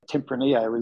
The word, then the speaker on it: Tempranillo: She'd correct you there because it's pronounced Tempranillo is a very nice drop over here.